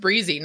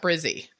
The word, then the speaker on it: Brizy: So it should be pronounced Brizy, not Brizzy.